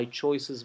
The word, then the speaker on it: choices: La palabra choices, la E final de choices, elecciones, se pronuncia casi como una I. Y fíjense que la C se pronuncia como una suave, pero la S final se pronuncia como una S zumbada. Choices. Choices.